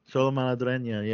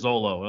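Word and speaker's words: Xolo Maridueña: So, Is that how you pronounce his name? I had no idea how you pronounce his name. – Yeah. Cholo Madrileña. Yeah. – It's just the opposite of whatever you think it would be. – Yeah. I thought it was Zolo.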